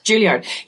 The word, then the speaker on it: Juilliard: How do you say that? – Juilliard.